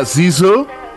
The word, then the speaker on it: SZA: En video ella dice que se pronuncia Sisu.